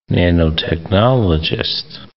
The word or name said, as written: nanotechnologist